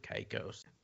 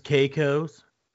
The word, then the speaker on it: Caicos: Turks and Caicos. Actually, it's pronounced Caicos.